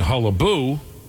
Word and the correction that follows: hullabaloo: We meant to say hullabaloo.